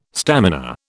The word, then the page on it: stamina: British English